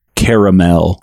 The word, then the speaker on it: caramel: It's pronounced caramel, not caramel. Caramel.